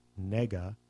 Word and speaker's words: Negga: Ruth Negga, N-E-G-G-A.